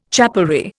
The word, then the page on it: chapelry: Indian